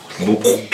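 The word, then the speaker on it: muqw'lha: No, no. Mukla. It's QW apostrophe. Mukla. See, everyone, we grew up saying it wrong.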